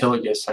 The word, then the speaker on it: Hillegas: So James Hillegas, I guess is how you say my last name.